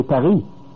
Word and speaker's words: Paris: In French also, you don't say Paris. You say Paris. So the S is mute.